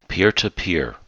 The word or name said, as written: peer-to-peer